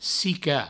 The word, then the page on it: desonsicar: Provençal; desounsicar